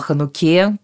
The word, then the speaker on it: Хануке: Мы сегодня говорим о Хануке, делаем правильное ударение об этом изумительном празднике.